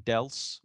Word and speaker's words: Dels: We had inspired by a question from Irene Dels, rhymes with else.